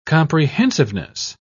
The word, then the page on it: comprehensiveness: British English